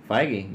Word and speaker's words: Feige: So see, Feige, you said it right.